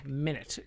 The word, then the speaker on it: Minit: Yeah, it's spelled M-I-N-I-T. – Right? Yes. Oh, it's M-I-N-I-T. – Yeah. – Okay, Minute. Got it.